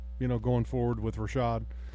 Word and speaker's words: Rashad: Well, first and foremost, JR, I want to just correct your pronunciation. It is Rashad, okay? – And Lagerman.